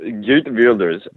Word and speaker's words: Geert Wilders: And the right-wing party of, I can't pronounce it, I think it's Geert Wilders, yeah. – Geert Wilders, which is a, you know, nationalist party, post-immigration.